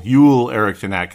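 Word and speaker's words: Joel Eriksson Ek: I think Eriksson Ek, Yule, Yule Eriksson Ek, because I'm saying it correctly now. I knew it before the last show, which is only not that long ago. I knew it, and I said Joel anyway because you just see Joel. No, it's Yule Eriksson It's spelled Joel, and it's the same name, but let's say it the way they say it, I suppose.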